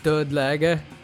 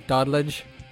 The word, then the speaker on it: Dudlega: They're called Dudlega. And I know that because I put the name into Google Translate, but I usually butcher the name by saying Doddledge, which is fucking bullshit, like.